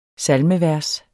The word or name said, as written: salmevers